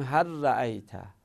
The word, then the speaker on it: هل رأیت: هل رأیت، بگیم هر رأیت، هر رأیت، اینطوری ادغام کنیم.